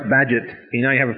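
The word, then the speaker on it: Bagehot: In the 19th century, when banks were collapsing a lot, it was a real pleasure, Walter Badgett, as far as I'm concerned, one of the most overrated political theorists and economists in the history of the world. Anyway, Walter Badgett, beloved by almost every historian, some obscurities.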